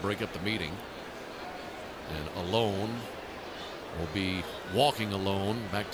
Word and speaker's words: Alon: It's spelled A-L-O-N. And I asked them, I said, is this pronounced Allen or is this, how do you pronounce this? They said, it's Alone.